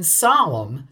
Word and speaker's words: solemn: And here, like phlegmatic, the N that's silent in solemn. – Solemnity. – Yeah. – But see, that makes sense, though, because solemn comes from the Latin solemnis, when the M and the N were both pronounced.